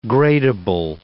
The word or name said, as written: gradable